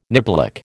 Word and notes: niblick: American English

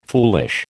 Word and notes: foolish: American English

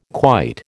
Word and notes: quite: American English

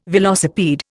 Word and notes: velocipede: Indian

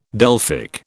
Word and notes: Delphic: British English

